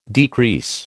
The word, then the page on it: decrease: American English